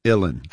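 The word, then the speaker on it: uilleann: It's Ilin. Like chillin', Ilin.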